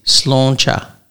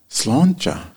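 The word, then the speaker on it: Sláinte: Well, but what I should be saying, at least approaching a more correct pronunciation is Slonjé. – Slonjé.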